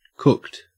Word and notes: cooked: Australian English

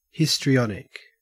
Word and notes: histrionic: Australian English

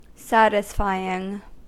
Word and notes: satisfying: American English

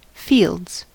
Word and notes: fields: American English

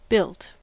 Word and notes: built: American English

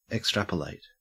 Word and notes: extrapolate: Australian English

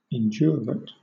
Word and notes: indurate: British English

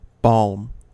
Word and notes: balm: American English

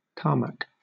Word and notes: tarmac: British English

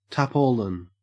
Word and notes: tarpaulin: Australian English

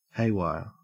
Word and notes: haywire: Australian English

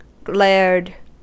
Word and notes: glared: American English

